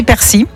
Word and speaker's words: persil: Alors normalement c'est persil, ok, ouais. D'après le dictionnaire de l'Académie française, mais le nord et le sud du pays prononcent quand même le L, donc t'as le droit quand même aux deux.